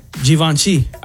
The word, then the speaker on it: Givenchy: Givenchy. – I've never even heard of that brand. – You're supposed to pronounce it with a Z, according to this. It's Z-H-E-E-V-O-N-S-H-E-E. Givenchy.